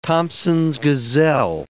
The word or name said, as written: Thomson's gazelle